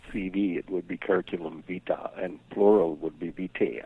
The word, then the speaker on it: vita: So I am looking at your curriculum vitae. How do you pronounce that anyway? I've never learned that. Curriculum vitae? Or? – I always say vita in the singular.